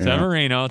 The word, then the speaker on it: Severino: And in a game that the Twins had all kinds of chances, they had bases loaded, nobody out, could not score against Severino. – Yeah. – Severino, thank you. I knew I was going to butcher that name.